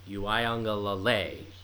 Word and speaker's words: Uiagalelei: Other notable commitments for this year, obviously, Uiagalelei, or how do you pronounce it? Go ahead. – Uiagalelei. It's like the accent is on the second leg.